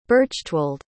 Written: berchtwald

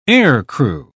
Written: aircrew

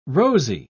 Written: rosy

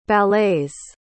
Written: ballets

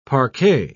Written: parquet

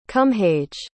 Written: cumhaige

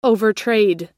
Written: overtrade

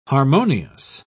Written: harmonious

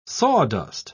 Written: sawdust

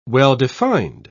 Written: well-defined

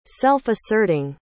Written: self-asserting

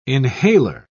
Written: inhaler

